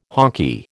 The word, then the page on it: honky: American English